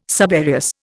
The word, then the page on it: subereous: Indian